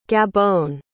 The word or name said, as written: gabon